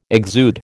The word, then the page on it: exude: American English